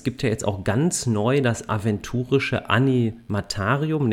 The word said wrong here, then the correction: Animatorium: Nee, das habe ich jetzt falsch ausgesprochen. – Ne? Animatorium.